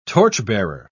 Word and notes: torchbearer: British English

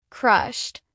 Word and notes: crushed: American English

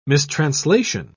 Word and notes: mistranslation: British English

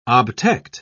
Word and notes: obtect: British English

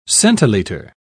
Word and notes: centiliter: British English

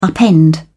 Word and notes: upend: British English

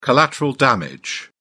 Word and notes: collateral damage: British English

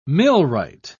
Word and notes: millwright: British English

